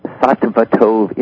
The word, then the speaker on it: Sattva Tov: The institute is called Sattva Tov Institute, and I know that's difficult for people to pronounce.